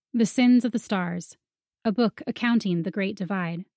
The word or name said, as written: The Sins of the Stars